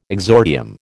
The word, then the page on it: exordium: American English